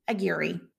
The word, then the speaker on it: Aguirre: But when I got married, I really had to practice saying Aguirre. All the way to work, every day I would practice rolling my R's. Aguirre, Aguirre, Aguirre. All that to come full circle and pronounce my name Aguirre.